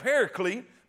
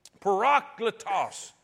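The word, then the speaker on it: Parakletos (παράκλητος): Now, in the word comforter here, I always heard it called Pericles, but that's not the way that's pronounced. That's not the way it's pronounced. I'm gonna mess it up now. Parakletos is the way the word is pronounced.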